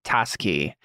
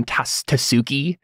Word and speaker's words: Taski: Today, we are with Connor, my brother, and we are talking about Cantarius Taski. Uh, I believe we uh, pronounced that name Tasuki in the first season, but that's not how you pronounce a Japanese last name, um, which um.